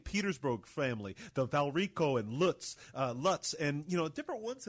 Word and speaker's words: Lutz: I know it's Lutz. I'm working on it. – It's Lutz. – It rhymes with boots.